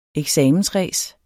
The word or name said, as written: eksamensræs